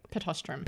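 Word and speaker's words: Pittosporum: Pitostrum. – Pitostrum. – It is. It's weird compared to how it's spelt, which is PITOSPORUM.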